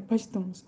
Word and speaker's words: Pashtuns: Pachuns. Pastuns. Agora eu falei certo, é Pashtuns.